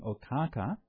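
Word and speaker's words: Okkāka: and the founder of the solar lineage was King Okaka, is the Pali pronunciation. The Sanskrit pronunciation is Ikshvaku, and Ikshvaku is derived from the word for sugar, or sugar cane.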